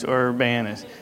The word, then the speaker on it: Urbana: Is that how you say it? I said it right this time? Okay, last time I said Urbana and stuff. So it's Urbana.